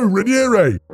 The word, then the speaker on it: Raniere: And I was like, okay, Raniere. Rainier! – No, no, Raniere!